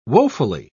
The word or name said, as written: woefully